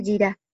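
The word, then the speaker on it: Jirá (יראה): Ahora, en sí misma la palabra que encontramos es la palabra Jirá. ¿Jirá? ¿Sí es que se pronuncia? Déjenme la reviso de nuevo. No me acuerdo bien. Sí, Jirá. Se pronuncia Jirá.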